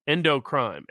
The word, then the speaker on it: endocrine: Yeah, somebody says in the locals chat, it's called phthalates and endocrine. Endocrine apparently is the pronunciation of the other word.